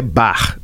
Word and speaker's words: Bach: E o Bar Mineiro, né, porque a pronúncia de bar é BA CH em alemão é bar.